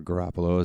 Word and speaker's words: Garoppolo: 49ers star quarterback Jimmy Garoppolo, or Garoppolo as it's pronounced,